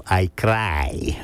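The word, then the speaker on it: I Craj: But nonetheless, this song here that I'm going to play tonight is entitled, it's spelled I, and then C-R-A-J. So I cry, the way that an Italian would pronounce I cry in English.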